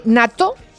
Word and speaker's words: natto: ¿Han oído ustedes hablar de nato? Así con doble T se escribe, N-A-T-T-O, nato